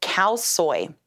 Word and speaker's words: khao soi: It's called khao soi. It's spelled K-A-O space S-O-I.